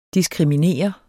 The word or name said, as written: diskriminere